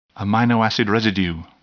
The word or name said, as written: amino acid residue